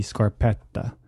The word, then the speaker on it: scarpetta: It's there to act as the scarpetta.